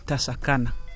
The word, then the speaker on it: Tasakana: That's correct, Tasakana, meaning let's get together and cooperate.